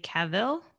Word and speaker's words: Cavill: When casting Batman, they wanted somebody taller than Henry Cavill.